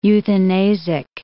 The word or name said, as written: euthanasic